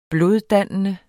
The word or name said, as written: bloddannende